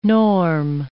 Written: norm

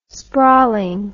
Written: sprawling

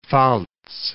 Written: Pfalz

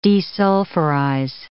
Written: desulfurize

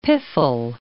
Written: piffle

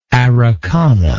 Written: Araucana

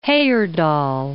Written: Heyerdahl

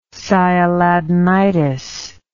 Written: sialadenitis

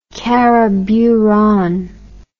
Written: karaburan

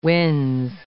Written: winze

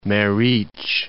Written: Meric

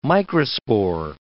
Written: microspore

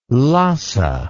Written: Lassa

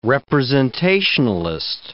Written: representationalist

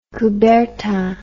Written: coubertin

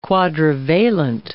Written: quadrivalent

